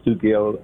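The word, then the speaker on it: Stucchio: I never knew that C-H is K. You know, Stuckio.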